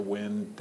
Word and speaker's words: wind: But there is this thing called the great vowel shift, where vowel sounds change. The word wind, for example, as the wind is blowing, up until the 18th century was pronounced wind. And so when you see Shakespeare, blow, blow, thou winter wind, thou art not so unkind, that was a perfect rhyme for Shakespeare.